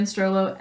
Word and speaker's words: Strollo: Or you can follow me at Leanne Strollo, L E I G H A N N S T R O L L O.